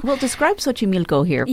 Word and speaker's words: Xochimilco: I feel like, please correct me if I say it wrong, Xochimilco? – Xochimilco. – Xochimilco.